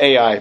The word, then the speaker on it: Ai: But after Jericho, the next city they had to attack was called Ai, it's spelled Ai. I don't know if you pronounce it I, or I always call it Ai, but I just spell it out.